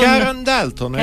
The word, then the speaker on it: Karen Dalton: Questa è Karen Dalton. No, Dalton. Non mi ricordo più. Dalton. – Karen Dalton, hai detto bene.